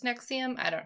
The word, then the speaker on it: NXIVM: 'Cause it's also not spelled out. It's, like, all caps, N-X-I-V-M. – Mm-hmm. – So it's just pronounced Nexium.